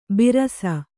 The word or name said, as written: ಭರಿಸು